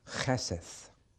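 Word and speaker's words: cheseth (חסד): In Hebrew, C-H-E-S-E-D, cheseth. You pronounce the C-H like the Scots pronounce loch.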